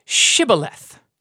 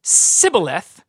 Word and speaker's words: Shibboleth: Then they would say to them, all right, say Shibboleth. And if the person said Sibboleth, because he couldn't pronounce the word correctly, they would seize him and they would kill him at the fords of the Jordan.